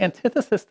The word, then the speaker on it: antithesis: That is the antithesis. Is that the right word? The antithesis. I basically just said it wrong. Got the wrong emphasis, right?